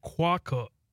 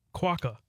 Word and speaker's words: quokka: Okay, so it's quokka. Quokka. – Okay. – Quokka. That's how you say it.